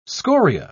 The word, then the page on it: scoria: British English